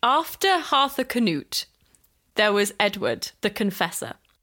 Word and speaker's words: Harthacnut: He had a son called Harthur Canute. – No. – All one word. – Arthur or Harthur? – Harthur Canute.